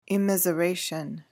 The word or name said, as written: immiseration